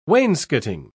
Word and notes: wainscoting: British English

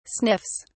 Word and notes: sniffs: English; British English

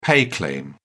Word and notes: pay claim: British English

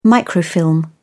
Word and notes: microfilm: British English

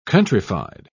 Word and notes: countryfied: British English

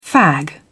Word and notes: fag: British English

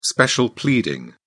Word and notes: special pleading: British English

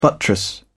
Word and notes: buttress: British English

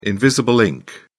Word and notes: invisible ink: British English

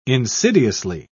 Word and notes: insidiously: British English

